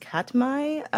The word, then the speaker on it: Katmai: I believe it's pronounced Katmai National Park and Preserve in Alaska.